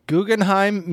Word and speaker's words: Guggenheim: The Guggenheim Museum in New York.